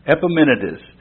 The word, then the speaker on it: Epimenides: Can you spell that word? Epimenides. E-P-I-M-E-N-I-D-E-S.